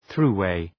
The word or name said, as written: thruway